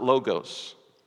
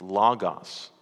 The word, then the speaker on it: logos: Just a quick pronunciation lesson. It's not logos. It's logos. The Greek word is logos.